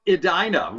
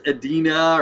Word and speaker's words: Edina: I'm impressed you know it's pronounced Edina. Well done, my man. Most everybody else is Edina or something else. I don't know how you know that, but you got it right.